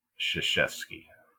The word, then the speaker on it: Krzyzewski: Krzyzewski.